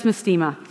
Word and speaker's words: Mastema: And is it mastemia? Is that how you pronounce it? I don't know. Yep, it's mastema.